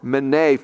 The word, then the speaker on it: menei: And it's the Greek word mene, M-E-N-E-I, mene is how I pronounce it.